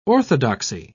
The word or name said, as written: orthodoxy